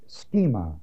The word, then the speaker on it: schema: Be not conformed is the Greek word schema, S-C-H-E-M-A, long E.